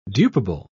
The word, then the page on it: dupable: British English